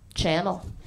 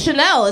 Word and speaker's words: Chanel: It would blow your mind how often people look at my name and instead of calling me Chanel, they call me Channel. To my fucking face. People just say that. I'm always like, no, it's Chanel. It's spelled like the perfume or the purse or multiple people throughout history and the free world.